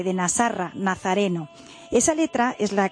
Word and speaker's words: Nasarra: Bueno, pues vamos a empezar explicando el título del documental, que sí ya nos dirá cómo se pronuncia, Nasarra. – Nasarra. – Nasarra, así, Nasarra, significa nazareno en árabe.